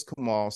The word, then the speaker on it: Kamal: So my name is Kamal.